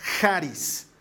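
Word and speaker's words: Haris (χάρις): En griego significa Haris, o perdón, se pronuncia Haris, así como Jason, Haris.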